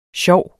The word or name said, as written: sjov